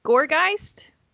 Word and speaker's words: Gourgeist: Okay, I picked Gourgeist.